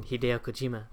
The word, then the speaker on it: Hideo Kojima: So Hideo Kojima.